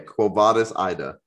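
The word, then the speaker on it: Quo Vadis, Aida: I think it's Quo Vadis, Ida. – Okay, Quo Vadis, Ida.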